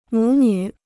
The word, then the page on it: 母女: mǔ nǚ